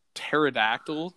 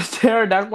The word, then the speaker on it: pterodactyl: You know, dinosaurs are raw, and then there's like the pterodactyl. – The pterodactyl, like the flying dude. – One. – Yeah, yeah, yeah. And it's funny; it's spelled funny too because there's a P.